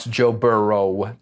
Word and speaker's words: Joe Burrow: we're gonna figure out how to pronounce Joe Burrow. – It's Joe Burrow. – He's not plural yet.